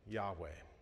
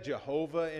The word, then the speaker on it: Yahweh: And then we pronounce it Yahweh. There we go. If it's all caps, I am, or all caps, the Lord, then it's Yahweh, those four letters. Sometimes we've said Jehovah in English, but that's probably a corruption of how it was originally pronounced.